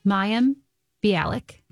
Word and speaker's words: Mayim Bialik: Well, Jeopardy's in the news because host Mayim Bialik.